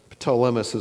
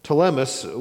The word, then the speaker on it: Ptolemais: We continued our voyage from Tyre and landed at Palatmus. Actually, this was the one I even looked up trying to figure out how to pronounce this, and I never figured it out. Ptolemais is what I'm going to say tonight. Ptolemais, where we greeted the brothers and stayed with them for a day.